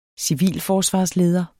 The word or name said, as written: civilforsvarsleder